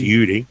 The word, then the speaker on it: Udy: it's not spelled E E W Y D Y. – It's pronounced Udi.